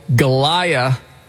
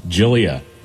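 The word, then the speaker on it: Gyliah: And 19-year-old Galia Johnson. – Okay. – Or is that Jalia? It's G-Y-L-I-A-H. Help? – Jillia. – Jillia? – Jillia? – Yeah. – G-Y-L-I-H.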